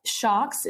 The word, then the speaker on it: Sjokz: He tweets, at Lauren Creedon should shout out at Shocks, spelled S-J-O-K-Z. She is and has done amazing things in esports as an on-air host and interviewer and more, against all odds. Shocks is how you pronounce her handle. It's a Dutch pronunciation.